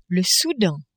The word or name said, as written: Soudan